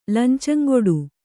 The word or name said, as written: ಲಂಚಂಗೊಡು